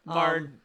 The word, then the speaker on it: Mard: Mard rhymes with large.